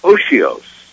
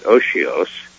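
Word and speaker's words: otiose: O-T, T as in Tom, O-T-I-O-S-E, O-T-I-O-S-E, it's pronounced, again, I think I got it right, Oshios.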